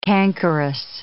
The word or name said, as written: cankerous